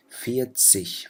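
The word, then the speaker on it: vierzig: بتلفظوها كحرف شين، فبتقولوا فيت سيش، فنف سيش، زيشت سيش.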